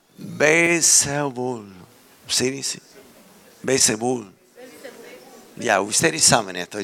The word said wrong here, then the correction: Belcebú: No lo dije bien. ¿Qué es? Belcebú.